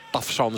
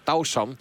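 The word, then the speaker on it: Tavşan: Nu gaan ze dat weer proberen met Tausan, heb ik me laten vertellen. Moet je het eigenlijk uitspreken. Tafsan zeggen wij. Wij spreken die V gewoon uit. Maar Tausan is het.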